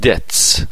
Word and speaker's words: debt: with debts, گفتم بی تلفظ نمیشه,